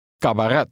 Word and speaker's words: cabaret: C also has a K sound when followed by an A, O, or U. The ending rhymes with let. – Cabaret.